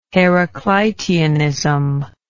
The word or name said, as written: Heracliteanism